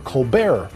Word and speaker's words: Colbert: And so he created the Colbert Report, Silent T.